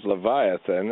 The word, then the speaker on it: Leviathan: And the Hebrew is Leviathan, and that's how it's pronounced, which is why we get that English transliteration of that, especially here.